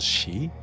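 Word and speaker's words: shi (四): 4 can be pronounced as shi and 9 as ku.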